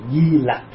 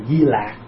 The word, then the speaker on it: Di Lặc: và ngày nay chúng ta thường đọc là Di Lặc Phật. Có khi thì người ta đọc chạy chút cho đọc là Di Lạc. Thật ra lạc lặc cũng được, nhưng mà đa phần mình đọc là lặc.